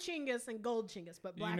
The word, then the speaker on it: Chinggis: Chinggis, as in Genghis Khan, but it's pronounced Chinggis, as you told me before we left, and it's true.